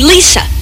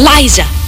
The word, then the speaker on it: Liza: People have this tendency to call me Lisa, and that's not it. My name is Liza. It's with a Z.